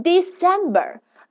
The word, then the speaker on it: December: 这里的 C 它发 s，OK，那前五个字母就这么出来了，对吧？December D E C E M，这里需要大家特殊注意的是两个 E，但是它们的发音是不一样的。第一个 E 它的发音是 e e e，第二个 E 它的发音是 i i i，所以这两个大家要搞清楚啊。 So 十二月份 December。